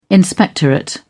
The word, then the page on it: inspectorate: British English